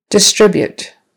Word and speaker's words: distribute: Distribute. Distribute. Second syllable stress.